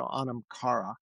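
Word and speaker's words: anam chara: And I'll tell you the way I was taught to pronounce it, the H is silent, so Anamkara.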